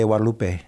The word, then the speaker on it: Guadalupe: After the parents of within 10 years, after it just is, and I like how our Mexican Spanish speaking friends will say Our Lady Guadalupe. You know, they don't pronounce the G; it's a W sound, and it's just it's a tremendous testimony to God's intervention.